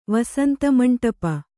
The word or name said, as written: ವಸಂತಮಂಟಪ